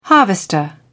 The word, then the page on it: harvester: British English